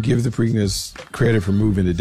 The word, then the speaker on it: Preakness: The Preakness? Preakness? – The Preakness.